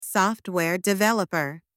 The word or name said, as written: software developer